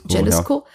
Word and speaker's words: Jalisco: Jalisco. Ja, ja, genau. Wir müssen es nicht Englisch aussprechen.